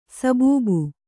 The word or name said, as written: ಸಬಬು